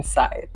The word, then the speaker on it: scythe: Like, the K in Scythe was pronounced. There is no K in Scythe, but I used to say Scythe.